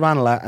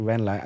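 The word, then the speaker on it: Ranelagh: No, it's called Ranala, and then sometimes people say Renla, and then they think it's posh to say Ranala, which is chilly because that's actually just the correct pronunciation.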